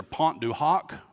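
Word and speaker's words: Pointe du Hoc: He was at D-Day with the Rangers at two different pronunciations for this, either Pont du Hoc or Pont du Ho, depending on which interpretation you read.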